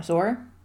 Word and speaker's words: Zoar: Sure, Zoar. – Yeah, Zoar, Zoar, I don't know how you pronounce it. Sorry, Connecticut. – It's like boar, but with a Z instead of a B.